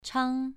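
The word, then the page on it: chang: tone 2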